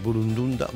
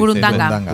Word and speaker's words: burundanga: Claro, si te inoculan mm, burundunda, burundanda, ¿cómo se dice? Burundanga.